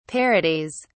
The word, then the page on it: parodies: British English